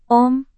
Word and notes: om: Indonesian

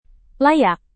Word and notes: layak: indonesian

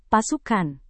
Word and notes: pasukan: Indonesian

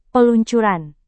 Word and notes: peluncuran: Indonesian

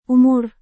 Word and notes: umur: indonesian

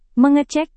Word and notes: mengecek: Indonesian

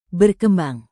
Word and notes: berkembang: Indonesian

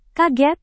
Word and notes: kaget: Indonesian

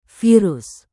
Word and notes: virus: Indonesian

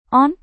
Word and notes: on: Indonesian

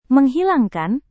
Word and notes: menghilangkan: Indonesian

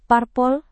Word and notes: parpol: Indonesian

